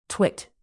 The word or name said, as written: twit